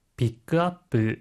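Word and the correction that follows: pick up: 픽업, 수령, 픽업이란 뜻인데요. 혹시 발음 팁 있을까요? – 하이, 다음에 주의해 주시고, 업이라는 말은 up라고 해야 합니다.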